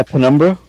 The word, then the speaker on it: Penumbra: Is it Penumbra? – Yeah, Penumbra.